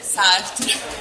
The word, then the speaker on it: Sartre: Jean-Paul Sartre, ragazzi. – Però l'hai pronunciato malissimo, Raffi, te lo dico, eh. Dillo tu, dillo tu. No, dillo tu, almeno la pronuncia giusta. Jean-Paul Sartre.